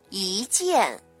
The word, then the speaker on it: 一见: 不是一成不变的，那么在去声前要读成三五的调值，比如一见，那么见是四声，那么一的发音呢就要变成一见。